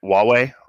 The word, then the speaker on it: Huawei: Huawei, right? That's how you pronounce that company's name. – Correct.